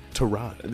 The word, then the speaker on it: Tyrod: Was it really Tyrod? – Yeah, that's how you say his name. Tyrod.